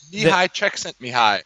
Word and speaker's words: Mihaly Csikszentmihalyi: Mihaly Csikszentmihalyi.